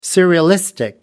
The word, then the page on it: surrealistic: American English